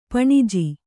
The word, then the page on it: ಪಾಣಿಜ: pāṇija